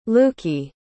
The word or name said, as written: Luki